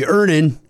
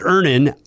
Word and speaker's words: Earnin: I'm going to pronounce this properly, Earnin. – Earnin. – E-A-R-N-I-N is pronounced Ernin. I don't know how else you would pronounce it, but they really want to be clear that that's how to pronounce it.